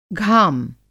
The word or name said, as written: घाम